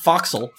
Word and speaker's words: forecastle: um, one time someone emailed in uh, saying, correcting my pronunciation of forecastle, which is foxel.